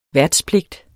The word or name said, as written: værtspligt